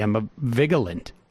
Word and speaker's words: Emma Vigeland: Emma Vigeland. – Yeah, there's three. Syllables.